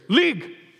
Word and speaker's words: Legg: So my last name, Legg, is actually a Scottish word. And the way it's actually pronounced there is Leg. And you have to yell it because it's Scottish. So it's Leg. It's where we get the word league, meaning a distance.